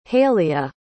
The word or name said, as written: haleigha